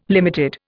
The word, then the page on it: limited: Indian